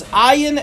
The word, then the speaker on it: Ayin: The right way to say it is a very guttural sound. It's actually an ayin. It comes from the back of the throat.